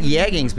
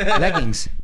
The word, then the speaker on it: leggings: United Airlines does not like yeggings, people. Two girls were stopped. Yeggings? leggings.